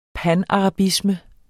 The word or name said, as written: panarabisme